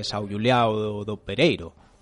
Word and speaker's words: São Julião do Pereiro: Bueno, habría que decirlo en portugués. Orden de São Julião do Pereiro.